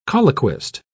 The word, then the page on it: colloquist: British English